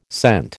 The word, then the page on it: cent: American English